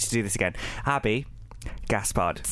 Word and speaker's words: Gaspard: The French pronunciation is Gaspard.